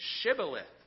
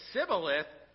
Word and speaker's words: Shibboleth: If he said no, they told him, please say Shibboleth. If they said Sibboleth, they could not pronounce it correctly.